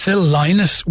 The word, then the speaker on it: Lynott: By the way, it's Phil Lynott. We did Thin Lizzy a couple of weeks ago, and I was lambasted for not pronouncing it correctly.